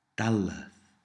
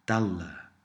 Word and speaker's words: dalleth: Sometimes the th sound at the end of dalleth will be dropped and you're left with dalla, dalla. But here we will use dalleth.